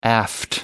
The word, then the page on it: aft: American English